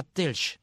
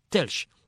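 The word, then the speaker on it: Telč: de un político que se llama Telch, se escribe T-E-R-C, con una pajarita encima, Telch, pero se escribe Telk, T-L-S-K, T-E-L-C.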